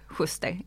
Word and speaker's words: Schuster: Uttalade jag det efternamnet rätt? – Typ. Schuster.